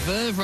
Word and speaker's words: Verve: Verve rhymes with swerve,